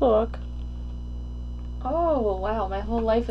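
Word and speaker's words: Lo'ak: Loak. – Loat. – No, it's K. – L-O-A-T.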